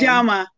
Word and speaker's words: Njama: Njama, like the magazine Njama.